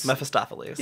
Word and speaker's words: Mephistopheles: Mephistopheles.